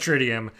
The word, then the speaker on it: tritium: Yes, deuterium and tritium.